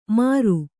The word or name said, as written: ಮಾರು